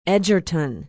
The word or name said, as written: edgerton